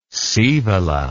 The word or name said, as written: scaevola